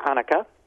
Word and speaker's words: Anneke: Yes, Matilda Franziska Anneke, I like to pronounce it as we do here in my German city, is in my first book and in my second book.